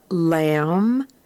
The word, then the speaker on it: lamb: In this case, the B is silent. And the words are lamb, jam, dumb, numb, crumb, thumb, bomb, bombed, bombing, climb, climbed, climbing, comb, combed, combing, plumber, plumbing, tomb.